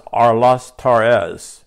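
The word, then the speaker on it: Aristarchus: They're a little difficult to pronounce, but it's pronounced Arlistarchus, and it's pronounced A-R-I-S-T-A-R-C-H-U-S, A-R-I-S-T-A-R-C-H-U-S.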